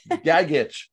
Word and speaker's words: Gagic: Gagic.